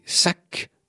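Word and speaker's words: sac (שק): Les jours de deuil ou de pénitence, ce pagne ancestral, appelé sac en hébreu, mais cela s'écrit S-A-Q, sera utilisé comme signe extérieur d'une misère profonde.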